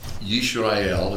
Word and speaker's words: Yisrael: Yisrael is the way it's pronounced.